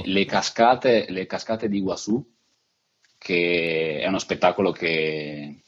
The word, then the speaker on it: Iguazú: E lì ci sono delle cascate fantastiche che si chiamano appunto Iguazú, con la Z e l'accento sulla U, e sono fantastiche.